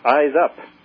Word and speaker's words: IZUP: It's spelled I-Z-U-P, as in Indigo Zebra Upsilon Paul, I-Z-U-P, and it's pronounced Eyes Up, as looking up to space. That's how it's pronounced.